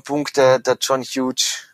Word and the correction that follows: Hughes: Huge, das ist ein Scheißname. Wieso kann er nicht Huge oder wie spricht man den wirklich aus? – Hughes. – Sagt er selber, mein Name ist John Hughes, weil man weiß es nicht genau, gell?